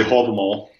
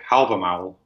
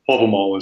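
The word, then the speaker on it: Hávamál: Did I say that correctly? – I would say Havamal. – Havamal. – And then a lot of other people would say Havamal, which is the modern Icelandic pronunciation. But Havamal is the reconstructed Old Norse pronunciation.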